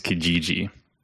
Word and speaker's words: Kijiji: By the way, if you're in Canada, your Canadian Craigslist is Kijiji. – Kijiji? – Kijiji. Yeah. – What? – I'm pretty sure like Ezra had mentioned that to me at one point, and I was like, Kijiji.